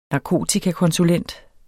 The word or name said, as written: narkotikakonsulent